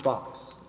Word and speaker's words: Fuchs: It's actually pronounced Fox, F-O-X, but it's spelled F-U-C-H-S.